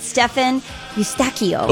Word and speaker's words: Stefan Eustachio: Oh, you were very excited to talk about Stefan Eustachio.